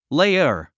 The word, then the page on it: layer: léiə r; レイヤァ